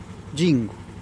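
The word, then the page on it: gingu': Teotitlán del Valle Zapotec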